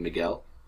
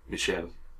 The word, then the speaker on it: Michel: One thing I really like in this movie is that Christina always pronounces his name Miguel, the Spanish pronunciation of Michael, whereas his name is Michel, the French pronunciation of Michael.